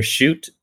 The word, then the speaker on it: Schutt: So I get it. They're Schutt. Okay. I never get the actual pronunciation of Schutt. – Schutt. Yeah, that's it.